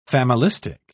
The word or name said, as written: familistic